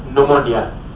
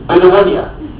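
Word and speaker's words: pneumonia: والنمونيا لو هي حرف البي معناها التهاب رئوي، والبي لا تنطق عشان ما تبقاش في الامتحان الشفوي ايه؟ بنمونيا.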